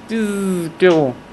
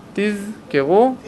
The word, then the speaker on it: תזכרו: אגב, בתוספות שם כתוב להתיז את הזין, ואנשים חושבים שלהתיז את הזין פירושו לבטא אותה עם דגש, תזכרו. זה טעות, זה טעות, לא צריך לומר תזכרו. להתיז את הזין פירושו לבטא את הזין בצורה מדויקת. תזכרו.